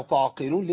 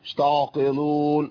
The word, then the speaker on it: تعقلون: خلاص ليحاجوكم به عند ربكم افلا تعقلون. ليه؟ أفلا تعقيق، قف مكسورة، الكلمة كلها تنطق بهيئة ابتسامة بسيطة. أفلا تعقلون، تعقلون، اشتعقلون، ما تنفعش خالص.